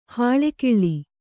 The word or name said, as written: ಹಾಲ್ಗಲ್ಲ